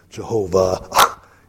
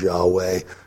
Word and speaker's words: Yahweh: If the W is pronounced like a V, you get Jehovah. And in later time, now in Hebrew, the W is pronounced like a V, the Vav. But way back, it was pronounced with a W. So they learned perhaps a better pronunciation as Yahweh when they started listening to the Hebrews, as they pronounced it.